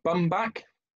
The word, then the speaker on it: Baumbach: Baumbach.